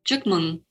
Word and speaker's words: Chúc Mừng: You pronounced that very well just now, by the way. So, yes, great work. So it's Chúc Mừng. – Chúc Mừng. – Chúc Mừng.